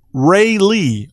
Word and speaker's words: Rayleigh: She got a response back saying, for a girl, Ray Lee, R-A-Y-L-E-I-G-H, but pronounced Ray Lee, like Ray Lewis and Lee as in Robert E. Lee. Ray Lee, Ray Lee, Ray Lee. I guess emphasis on the Lee.